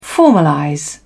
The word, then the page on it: formalize: British English